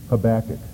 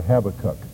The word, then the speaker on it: Habakkuk: His name is Habakkuk, or Habakkuk, as some people pronounce it.